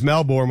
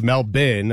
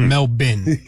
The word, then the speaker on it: Melbourne: Well, I thought it was Melbourne when we started today, but it's Melbourne, okay. And that is courtesy of Noah Eagle. – Yeah, so Melbourne. The Australian pronunciation, is Melbourne.